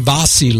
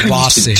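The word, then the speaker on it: Vassy: How do you pronounce it? She's from Australia. V-A-S-S-Y. I think it's Vossi.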